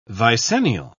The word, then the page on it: vicennial: British English